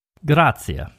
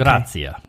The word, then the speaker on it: grazie: Oh, I always say grazie. I'm saying it wrong. You are. It's okay. grazia. – You, you kind of pronounce the I and the E after the Z. Grazia.